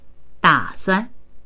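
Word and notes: da3suan: Mandarin

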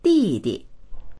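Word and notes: di4di: Mandarin

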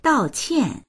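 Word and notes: dao4qian4: Mandarin

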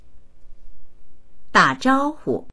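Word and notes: da3zhao1hu: Mandarin

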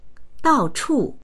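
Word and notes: dao4chu4: Mandarin

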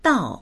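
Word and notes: dao4: Mandarin

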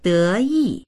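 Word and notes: de2yi4: Mandarin

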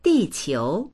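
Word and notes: di4qiu2: Mandarin

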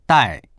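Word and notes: dai4: Mandarin

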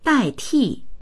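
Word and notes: dai4ti4: Mandarin

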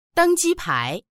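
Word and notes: deng1ji1pai2: Mandarin

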